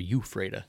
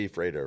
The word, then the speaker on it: Ephrata: Is it Ephrata or Euphrata? Because I think I grew up just calling it different. – Well, we all say Ephrata around.